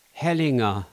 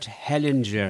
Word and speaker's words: Hellinger: And for any of you who find themselves in a situation, check out a guy called Bert Hellinger, or as the English pronounce it, Bert Hellinger.